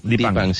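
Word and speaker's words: Dheepan: Dipang, ¿lo pronuncio bien? – Dipang, sí.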